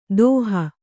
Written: ದೋಹ

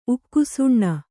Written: ಉಕ್ಷಣ

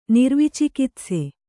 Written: ನಿರ್ವಿಚಿಕಿತ್ಸೆ

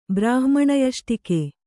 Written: ಬ್ರಾಹ್ಮಣಯಷ್ಟಿಕೆ

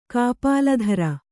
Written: ಕಾಪಾಲಧರ

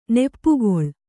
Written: ನೆಪ್ಪುಗೊಳ್

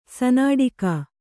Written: ಸನಾಡಿಕಾ

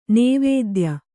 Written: ನವೋದಯ

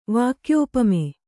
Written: ವಾಕ್ಯೋಪಮೆ

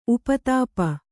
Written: ಉಪತಾಪ

